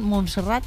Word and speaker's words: Montserrat: En la agenda de hoy tiene previsto acudir a la cárcel de Brians, visitar Montserrat y celebrar una misa en la Basílica de la Sagrada Familia. ¿Lo he dicho bien? ¿Montserrat? – Sí, Montserrat.